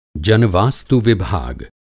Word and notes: जन-वास्तु विभाग: Hindi

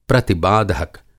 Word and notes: प्रतिबाधक: Hindi

